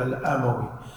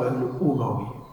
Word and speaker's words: الأموي: ترى كثير ناس ينطقها بالفتح هذا خطأ يقول الأموي لا صواب الأموي بني أمية بضم الهمزة